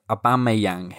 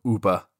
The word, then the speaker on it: Aubameyang: Aubameyang hit the post. Oh, I hate it when people call him Uber. People call him Uber. That's a car company.